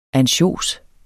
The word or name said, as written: ANSJOS